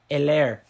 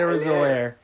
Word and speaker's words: Edwards-Helaire: How do you say that name? Edwards. – Hilaire. Clyde Edwards-Helaire.